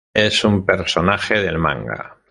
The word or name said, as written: manga